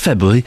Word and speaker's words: February: February at 3 o'clock. There is an R there that you should pronounce. It's February. The R's there. Why not say it?